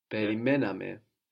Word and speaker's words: Περιμέναμε: We're going to have the accent third from the end. – Περιμέναμε. – Περιμέναμε.